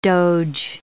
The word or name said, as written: doge